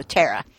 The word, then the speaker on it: Tara: Basically, I know someone whose first name is Tara and who habitually pronounces it with a short A like tap or taxi. So Tara.